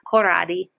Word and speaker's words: Corradi: It's spelled C-O-R-R-A-D-I, Karate. Just think of it with an Italian accent, Corradi.